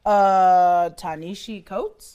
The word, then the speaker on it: Ta-Nehisi Coates: Ta-Nehisi Coates. I believe is how it's pronounced.